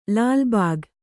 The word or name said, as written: ಲಾಲ್‍ಬಾಗ್